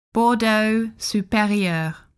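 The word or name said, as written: Bordeaux Supérieur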